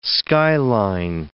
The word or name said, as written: skyline